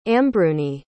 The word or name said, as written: ambruni